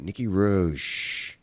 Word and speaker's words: Nicky Roche: My guest today is Nicky Roche. I want to say that right. Nicky Roche.